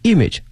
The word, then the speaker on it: image: 一个是 product development，在产品的开发 and taste innovation innovation 表示创新， taste 口味创新 and image building，后面这个词非常重要， image image，首先它的发音呢它的重音在前面， image 不要把重音放到后面了哈。